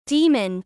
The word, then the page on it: Daemon: American English